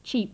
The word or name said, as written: cheap